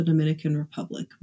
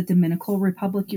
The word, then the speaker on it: Dominican Republic: Per the Charlie Project, on July 19th, Phillip returned home from a trip to the Dominican Republic. Remember, I mentioned earlier that he was very excited about this trip. – It is the Dominican Republic, you guys. She mispronounced that.